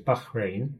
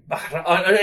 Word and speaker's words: Bahrain: Well, I asked that, and you, you did correct my pronunciation, so it's Bahrain. – Bahrain.